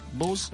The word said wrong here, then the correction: Bosse: Bosse. – Yeah, Bosse. Bosse. Yes.